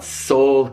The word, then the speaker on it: Seoul: What about Seoul, Korea? Same pronunciation as I have a soul, Seoul, Korea.